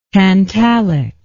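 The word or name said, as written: tantalizing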